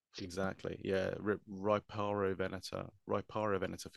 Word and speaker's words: Riparovenator: We actually CT scanned both Ceratosuchops and Riparovenator. I just realized I'm pronouncing it Riprovenator, where it should be Riparovenator or Riparovenator.